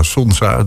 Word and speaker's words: Sonza: Sonza, zeg ik dat goed? Ja, Sonza, doet me denken aan de zon.